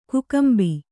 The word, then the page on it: ಕಕ್ಕಂಬು: kannada